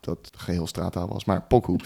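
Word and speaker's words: Poku: Ja, volgens mij spreek je het uit als Pokku.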